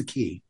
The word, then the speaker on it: Quay: So they docked at a place called the Quay, or the Quay is the way it's spelled, but it's pronounced the Quay.